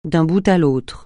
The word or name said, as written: d'un bout à l'autre